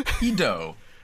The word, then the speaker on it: Oedo: Yeah, well, yeah, I think you said it Odeo, I said Odeo. It Odeo. It's O-E-D-O, it's Oedo. It's so very clearly an O-E-D-O.